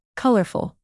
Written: colorful

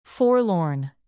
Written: forlorn

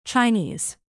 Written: chinese

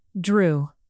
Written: drew

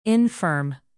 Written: infirm

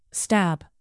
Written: stab

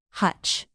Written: hutch

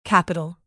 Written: capital

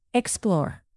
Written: explore

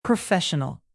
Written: professional

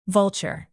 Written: vulture

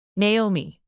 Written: naomi

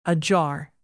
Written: ajar

